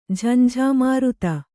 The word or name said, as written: ಝಂಝಾಮಾರುತ